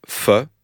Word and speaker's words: pho: C'est comme ça qu'on prononce pho ? – Pho. – Pho. – Pho. – P-H-O. – Oui. – En aucun cas, c'est faux. – Non. – C'est faux de dire faux.